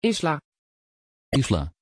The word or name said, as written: islay